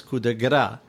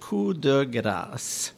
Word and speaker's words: coup de grâce: So very often, that phrase, coup de grâce, is pronounced in the U.S. as coup de gras.